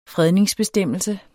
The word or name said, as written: fredningsbestemmelse